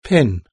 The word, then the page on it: pin: British English